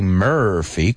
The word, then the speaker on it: Murphy: We say Murphy, como si fuera una E.